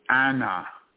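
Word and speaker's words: Anna (أنا): The pronoun I in the Quran, which is pronounced Anna, is actually the ancient Sumerian deity Anu or An, which actually just means heavens.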